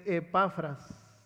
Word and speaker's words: Epafras: Como lo habéis aprendido de Epafras, así se pronuncia, nuestro consiervo amado, que es un fiel ministro de Cristo para vosotros.